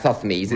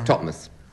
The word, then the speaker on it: Thothmes: Just like Thothmes, it's Totmes.